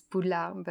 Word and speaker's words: Poudlard: And it's funny because the translator chose a word that could look English for a French reader, but we pronounce it in a very French way. It's Poudlard, but when we see Poudlard, we can think it's English and not French, but that's funny.